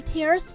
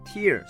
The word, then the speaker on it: tear: He tears the book in half，他把书撕成两半。 – 原来它有两种读音哦，我学起来了。 tear 是名词，意思是眼泪， tear 是动词，意思是撕开。